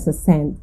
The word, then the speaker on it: Sxxnt: Bueno, su nombre normal es Stephen Bradley y su seudónimo es Sand, pero se escribe S-X-X-N-T.